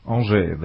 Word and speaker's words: Angers: Angers.